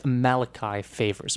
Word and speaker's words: Malachi Favors: The second apology, when I did the Malachi Favors show in memoriam, I mispronounced Mr. Favors' name, and, well, thankfully I was corrected on that matter, so my apologies for that as well. It is Malachi Favors, but, you know, that's the sort of thing you can't really intuit, you know, you have to hear it to make certain that it's being pronounced properly, kind of like Cal Chater, which you might think is Chatter, but no, Cal Chater. So these are things to keep in mind.